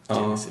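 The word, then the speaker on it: Genisys: Inte som Phil Collins Genesis. Nej, för det är med I. Genesis. Ja, precis.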